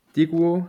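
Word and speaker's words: Daegu: Mhm wo wir gerade bei, ähm, bei der Tabelle der K-League sind, also Deguo, habe ich es richtig ausgesprochen, FC? – Ja, genau so kann man es sagen.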